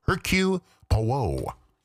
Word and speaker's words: Hercule Poirot: And yes, his name is pronounced Hercule Poirot.